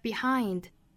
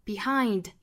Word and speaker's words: behind: 那再和前面的连读同化一起读呢，就变成了 what you've left behind. 这个 left 尾部特省读 left behind。 Behind 一定要注意不要读成了 behind，是 ein。